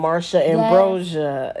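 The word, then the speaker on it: Marsha Ambrosius: Then I want to go into Flowetry and Marsha Ambrosia.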